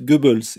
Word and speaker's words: Goebbels: Et d'abord, je vous prierai de prononcer Joseph Goebbels et pas Joseph.